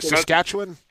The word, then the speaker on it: Saskatchewan: is that Saskatchewan? – Yeah, that's exactly it. – Yeah. I got to give guys a break. Like, it's not an easy, and you don't pronounce it the way it looks,